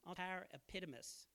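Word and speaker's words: Paraepidimios (παρεπίδημος): I don't think I said that right. Paraepidimios. There you go. Better.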